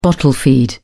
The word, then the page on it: bottle feed: British English